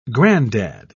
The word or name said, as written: granddad